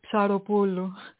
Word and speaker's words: Psaropoulou: Actually, PS is one letter in Greek. It's mm-hmm. It looks like a tripod, and it's pronounced Psa, Psi. – Okay. – So, yeah, so it's Psaropoulou.